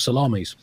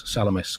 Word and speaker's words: Salamis: Anyway, the todger-like shapes described by the Athenian and Spartan ships gave the battle its name, the Battle of Salamis, often mispronounced, of course, as Salamis, coincidentally the name of the nearby island.